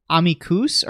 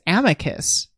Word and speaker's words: amicus: But they're both her and Lindsey Graham were pronouncing it amicus or like something like that. It was like a weird pronunciation of it, and I'm like, no, I'm pretty sure it's amicus, right? Like that's Latin. Because when I was in the fraternity,